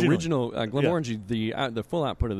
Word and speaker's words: Glenmorangie: Glenmorangie. – So the emphasis is on more. – The second syllable. Like Glenmorangie. You always want more of it.